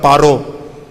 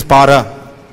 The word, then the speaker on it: Para: So like we don't say Sri Radhika Madhavayora Paro, it is Para, Madhavayora Apara, just because those who know the pronunciation should do well.